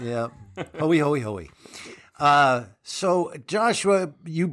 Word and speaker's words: Hoey: We were debating how to pronounce Dennis Hoey's name, even though it's in the recording. We didn't bother to pay any attention to it. – I'm sure it's a Dennis Hoey.